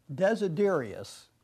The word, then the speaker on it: Desiderius: Desiderius.